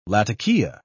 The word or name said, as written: latakia